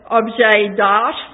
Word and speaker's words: objet d'art: They don't have objet d'art like we do." Is that the French pronunciation? Objet d'art. That means what? D'art. No T.